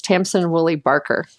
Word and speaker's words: Tamsin Woolley Baker: Oh, I keep saying her name wrong. Sorry. It's Tamsin Woolley Baker.